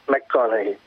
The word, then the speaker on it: McConaughey: McConaughey.